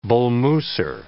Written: Bull Mooser

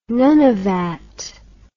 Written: Nunavat

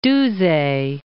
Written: Duse

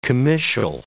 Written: comitial

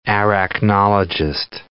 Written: arachnologist

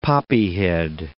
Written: poppyhead

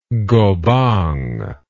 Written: gobang

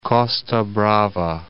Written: Costa Brava

